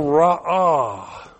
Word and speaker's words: ra'ah: They will ra'ah.